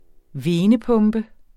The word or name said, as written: venepumpe